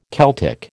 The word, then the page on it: Celtic: American English